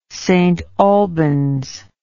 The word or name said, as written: stalbans